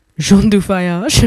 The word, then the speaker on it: Jean du Voyage: So we're going to open up with Sensitive by French producer Jean du Voyage, pronounced Jean du Voyage, my best French accent.